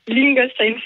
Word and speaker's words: Lingolsheim: Lingolsheim, c'est ça. – Lingolsheim, d'accord. Dans le Bas-Rhin, donc. – On dirait une chanson de Jean-Jacques Goldman. – J'étais né à Lingolsheim.